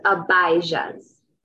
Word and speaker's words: Abijah: Yeah, it's Abijah's. – Abijah, long I.